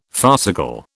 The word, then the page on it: farcical: British English